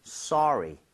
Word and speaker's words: sorry: 대신에 발음할 때 소리는 좀 심하죠. 소리는 아니니까 써에 가깝게 입모양 살짝만 벌려주고 발음했으면 좋겠어요. 그래서 Yes. – 소리 이렇게 하지 마시고요. Sorry, sorry.